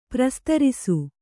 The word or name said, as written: ಪ್ರಸ್ತರಿಸು